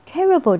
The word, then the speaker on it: terrible: Terrible 这个字呢，它有三段的发音，高音在第一段。